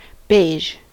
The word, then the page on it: beige: American English